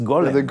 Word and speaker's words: Golem: Gollum. It's Golem. – Gollum is from the Jewish tradition. Yeah. – I think he borrowed. – Oh, I'm sure he did. Yeah. So, but they pronounce it Gollum.